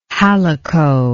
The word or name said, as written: halicot